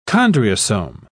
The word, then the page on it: chondriosome: British English